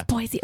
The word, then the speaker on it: Boise: All right, so this 3-year-old in Boise, that's how you say it, right? – Yeah. – Boise.